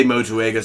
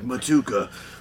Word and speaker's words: Motueka: Yeah, there's a couple ways to say that. I say Motueka, some people are like, Motuka. Motueka. I say Motueka.